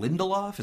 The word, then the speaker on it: Lindelof: So this guy, the guy behind this is Damon Lindelof.